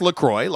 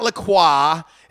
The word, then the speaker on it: LaCroix: Officially, it's pronounced LaCroix, like I said, so it rhymes with enjoy. It's not LaCroix. It's not LaCroix. Like the French may say it, it is LaCroix.